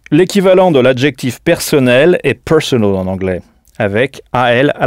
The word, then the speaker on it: personal: Il faut bien frapper la première syllabe, personal.